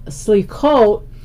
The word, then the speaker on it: Slichot: Prayers for forgiveness, called Slichot, which is actually the Sephardic pronunciation, the Ashkenazi is Slichus.